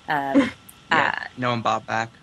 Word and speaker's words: Baumbach: And you see those with, like, the Noah Baumbach movies. I think that's how you say his name. – Noah Baumbach.